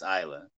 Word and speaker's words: Islay: It's spelled Islay, which looks like Islay, but it's Islay. And yeah, Islay. It's as peaty as it gets.